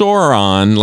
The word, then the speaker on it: Sauron: It's pronounced Sauron, like sore on. But hey, oh, Sauron. Sauron? No. – Sauron? – Sauron? – It's Sauron. – Oh, Sauron's it's not an A, it's a U.